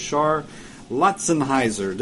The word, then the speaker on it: Char Lautzenheiser: And the director is Char Lautzenheiser.